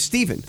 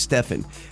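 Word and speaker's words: Stephen: I kid you not, almost 90% of the time I go to a restaurant and they see my name, they pronounce it Stefan. There is a, because it's a PH.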